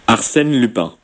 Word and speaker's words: Arsène Lupin: Arsène Lupin. – Arsène Lupin, así lo pronuncia un francés y así lo pronuncia una francesa nativa. – Arsène Lupin. – Arsène Lupin es el nombre de esta serie francesa que Netflix acaba de estrenar, que presentó 5 episodios y que es realmente espectacular.